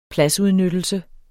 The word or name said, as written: pladsudnyttelse